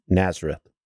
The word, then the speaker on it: Nazareth: He would be born in Nazareth and be spurned by others because of this. He would be born in where? Nazareth.